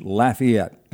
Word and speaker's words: Lafayette: And the Lafayette, by the way, I've been trained that it's Lafayette in Louisiana.